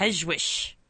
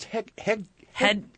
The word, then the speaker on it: Hegewisch: H-E-G-E-W-I-S-C-H. Mm-hmm. Hedgewish. That's my guess. – No, I think it's Hegwish. – Hegwish? – Isn't that how it's pronounced? – Hegwish? – Yes.